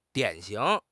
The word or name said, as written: 典型